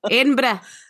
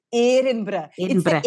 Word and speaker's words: Edinburgh: Inbra.